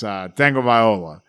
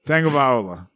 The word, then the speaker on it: Tagovailoa: It's all the talking heads at ESPN said it right. It's Tagovailoa.